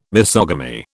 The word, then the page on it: misogamy: British English